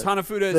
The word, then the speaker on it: Hanafuda: Did I say that right, Hanafuda? – Yes, Hanafuda is correct.